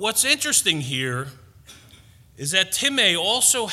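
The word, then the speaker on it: timē: Well, this word honor is spelt like time, T-I-M-E, but it's pronounced time.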